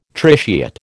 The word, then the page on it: tritiate: American English